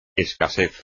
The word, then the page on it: escasez: espagnol